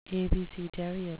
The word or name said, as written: abecedarian